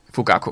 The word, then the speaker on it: Fugaku: Men Nok, jeg ved ikke, om den skal være kraftigere end, øh, hvad hedder den, Fukugachi? Fugak, Fugaku. Fugaku. – Fugaku, det er sådan, man udtaler det. Fugaku.